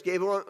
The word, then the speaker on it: Gabriel: Gabriel, I said it right.